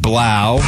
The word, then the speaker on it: Blau: Cardinals agreeing to terms with Zach Pascal and David Blau. Because it rhymes with cow.